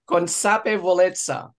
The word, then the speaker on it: consapevolezza: It rhymes with pizza. Consapevolezza.